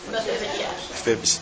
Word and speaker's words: Mephibosheth: How do you say his name? – Mephibosheth.